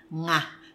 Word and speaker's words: ng: Nga. – Enye. – Enye, NG. – And NG. – Yes, yes. But the pronunciation of NG is nga, and the pronunciation of enye is nye.